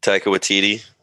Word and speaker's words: Waititi: Taika Waititi. How do you say it? – Taika Waititi.